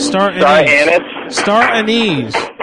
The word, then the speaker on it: star anise: Anises? What? Star anise. – What's that? – Star anise. It's called star. Okay. It's pronounced star anise.